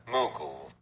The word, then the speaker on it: Mughal: In the 18th century, India was nominally ruled by the Mughal Empire. I bet I'm saying that wrong, aren't I? – Mughal. – Yeah, that sounds more plausible.